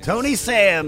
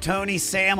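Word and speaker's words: Toeknee Sam: So, like toe, like on your foot, knee, like on your leg, and Sam, like your Sam.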